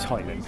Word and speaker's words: Haaland: So it's Hoyland. Just, it's Hoyland.